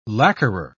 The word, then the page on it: lacquerer: British English